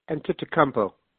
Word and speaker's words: Antetokounmpo: You look at, I can't even pronounce the kid's name, Giannis Antetokounmpo.